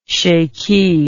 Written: shaikhi

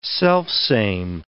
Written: selfsame

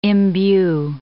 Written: imbue

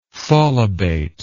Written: tholobate

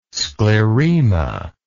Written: sclerema

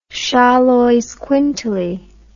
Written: xoloizcuintli

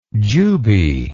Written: jube

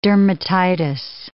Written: dermatitis

